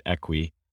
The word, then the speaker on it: Equi: So we actually pronounce it Equi, and it's actually Latin origin.